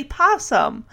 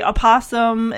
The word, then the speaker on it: opossum: But I've heard some, most people say possum. So you say possum long enough, you're gonna forget. Well, there's an O in front of it, which it's a it's a silent O.